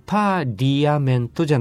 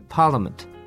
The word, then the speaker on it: Parliament: しかし、パーリアメントじゃなくて、発音は実際には Parliament という発音になります。